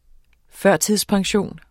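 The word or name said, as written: FØRTIDSPENSIONEN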